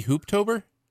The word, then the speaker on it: Hooptober: Well, it sounds it's called Hoptober, H-O-O-P-tober, Hoptober. – Wouldn't that be Hooptober? – Hoop? What did I say? Hop? Hop? Yeah. Hoop? Yeah, whatever. – Yeah. – I said it right the first time, I think. Hooptober.